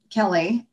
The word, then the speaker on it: Kelle: So my name is spelled K-E-L-L-E, Kelly, and I actually like that.